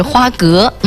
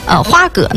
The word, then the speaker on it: 花蛤: 那很多人也分不清什么黄蚬子和花蛤啊，那咱们把这个音儿要是发的再接近咱们口语化的话哈，有人就叫它花蛤是吧，像我可能就会把它叫花蛤，但是正常的这个发音是花蛤。这比如说我这说了这个正确的发音之后，大家就不知道的是什么了啊。那么丹东人呢就管常见的这个花蛤呢叫什么，叫黑蚬子。